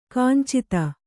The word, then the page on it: ಕಿಂಚಿತ್ತು: kannada